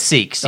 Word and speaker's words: Sikhs: So, uh, Alberta is uh, going to allow, did we say it right? Sikhs? – It's Sikhs, okay. yeah.